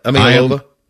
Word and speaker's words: Iamaleava: Give me the pronunciation. Is it Imalova? Uh, Imalova. – You got it, chops.